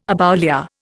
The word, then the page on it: aboulia: Indian